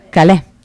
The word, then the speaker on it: Calais: Ci danniamo per raggiungere Calais, com'è che si pronuncia? Calais, scusate, in autostop.